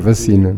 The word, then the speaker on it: vacina: É vacina, não é? Eh, não, não tem acento no I, portanto não se deve eh, eh, forçar a, a, a, a sílaba.